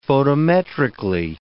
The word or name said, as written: photometrically